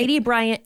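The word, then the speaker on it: Aidy Bryant: A.D. Bryant. Did I say that right? – A.D. Bryant and Nick Kroll.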